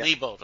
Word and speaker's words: Lebold: Lebold, okay.